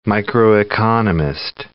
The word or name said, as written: microeconomist